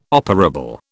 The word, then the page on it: operable: British English